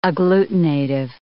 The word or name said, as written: agglutinative